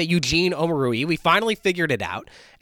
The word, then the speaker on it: Omoruyi: no, it's Omoruyi. So I guess that's how you say his name. You say Omoruyi.